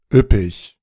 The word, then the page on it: üppig: üp·pig